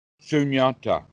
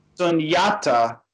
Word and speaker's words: Sunyata: Is it pronounced sanatta? – Sunyata. – Sunyata.